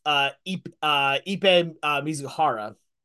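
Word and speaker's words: Ippei Mizuhara: Here, I'm pulling up the name, making sure here of Ipe Mizuhara. I want to make sure I'm saying that right.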